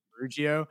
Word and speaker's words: Ferrugio: But my name is Ferrugio.